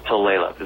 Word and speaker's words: Tulalip: So Tulalip is the way it's pronounced.